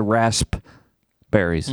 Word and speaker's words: raspberries: I've never cared for raspberries. Mm, I really like raspberries. They go with a lot of different things. – Pronounce the P, please. – Raspberries. – What? Why is there a silent P in there?